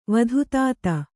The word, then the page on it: ವೇದಾತೀತ: vēdātīta